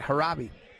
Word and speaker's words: Hraby: Yeah, White, Tanner Raby. Tanner Harabi, I'm sorry. Harabi, H-R-A-B-Y, Harabi.